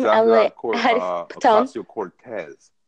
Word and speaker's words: Ocasio-Cortez: Ocasio-Cortez.